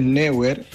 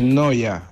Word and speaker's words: Neuer: No es Neuer, es Noya.